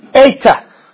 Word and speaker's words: Eta: Eta. Eta. Now, it looks like a H, but it ain't no H. It is the long A sound.